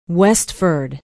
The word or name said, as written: westford